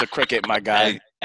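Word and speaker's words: Cricut: Is that how you say it? Because I thought it was cry cut.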